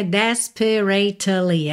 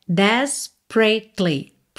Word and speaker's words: desperately: Desperately, aqui você não pronuncia dois S, tá? Não é desperately, é desperately, pratly. Pensa P-R-A-T-L-Y, tá? Desperately pondered.